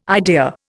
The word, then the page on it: idea: Indian